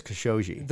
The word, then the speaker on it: Khashoggi: Is it Khashoggi? I know everybody pronounces it so many different ways. – I've heard it a lot now, and I believe it's Khashoggi.